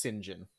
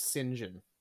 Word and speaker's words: St. John: Yeah, it's pronounced Sinjin. – What? – Yep. This is a wild British thing, but his name is Sinjin. Yep. – What? – Yep. Sinjin. Like, sin, like the bad thing that the Catholics don't want you to do.